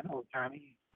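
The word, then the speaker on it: Ohtani: Well, so Tani, how do you say his name again? Otani? – Yeah.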